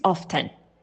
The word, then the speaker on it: often: The right pronunciation is often, right? In English, it's true that many words go with a silent T, right? So naturally, people assume they should pronounce it without T, but it is not. For example, after also has a T, and 50 has a T, and it's pronounced, so often is pronounced the T.